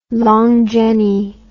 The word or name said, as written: long jenny